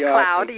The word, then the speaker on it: McLeod: Am I saying it correctly? McLeod? Some people say McLeod. – McLeod, yes.